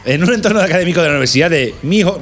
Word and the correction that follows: Miño: Mino, o cómo se dice. Miño. Miño. Miño.